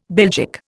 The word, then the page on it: Belgic: Indian